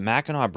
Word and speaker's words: Mackinac: Now, it's not Mackinac, that's how it's spelled, but the Mackinac Bridge, it's a bridge that, when I used to live in Michigan, my grandparents were from Michigan.